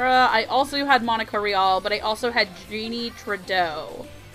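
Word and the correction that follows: Tirado: I think it's pronounced Torado, I believe. – Oh, sorry, Jeannie Torado.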